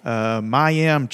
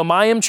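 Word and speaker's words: mayim (מים): I don't speak Hebrew, but the way you would pronounce this, and I'm probably going to butcher it, but I'm going to do my best, would be Mayim. Because you always put in Hebrew, the emphasis is on the second syllable. So it's mayim, kaim.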